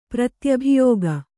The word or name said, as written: ಪ್ರತ್ಯಭಿಯೋಗ